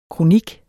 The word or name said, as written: kronik